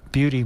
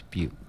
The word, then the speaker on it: Butte: He'd been to Beauty, Montana. And I said, no, it's Butte.